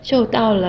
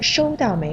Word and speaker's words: 收到: 啊，这是是因为我今天比较开心，昨天我收到了玫瑰花。 – 哦，原来是这样啊。嗯嗯，不过爱晶有个小小的发音问题，应该是收到玫瑰花，一声收。你刚才说的是四声受，收到和受到的意思是不一样的。